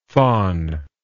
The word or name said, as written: phon